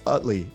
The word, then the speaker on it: Utley: It's Utley.